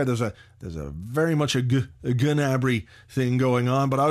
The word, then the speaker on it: Gnabry: And they definitely don't pronounce the G. There's a sort of a hint of it. It's like Gnabry. But definitely no Gnabry.